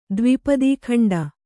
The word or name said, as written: ದ್ವಿಪದೀಖಂಡ